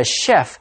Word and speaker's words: chef: 谁是这一餐美食的厨师?今天课文作者提到他们在海产市场挑了一些螃蟹跟鱼 for the cooks to prepare, 让厨师们烹煮。除了 cook, 还有 chef,C-H-E-F, 读作 chef, 这个字原本来自法文表示 chief, 就是一个长官领袖。所以一个 chef 在厨房里的领袖指的就是大厨主厨。这就是今天的 InfoCloud, 我们下次云端见。